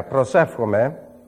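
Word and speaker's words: prosefchomai (προσεύχομαι): How would you say that, Wayne? Prosekomai? Oh, okay. Yeah, prosefchomai, which means I pray.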